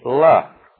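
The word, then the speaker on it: la (לְ): It is the word L-E, la. Preposition L-E, pronounced la, or with a very, very short E into it, and it means literally with reference to.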